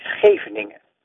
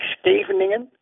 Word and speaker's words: Scheveningen: So if the Dutch didn't trust someone, they let them say Scheveningen, the name of a Dutch place. But if the person pronounced it right, like me, it was definitely Dutch. If you pronounce it like Scheveningen, you must have been a spy.